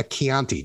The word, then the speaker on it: Chianti: So that's why I would recommend a Chianti.